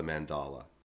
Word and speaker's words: mandala: And it was the sand routine that's called, am I saying this right, mandala? Is that how you pronounce that? – Yeah. Mandala, yeah.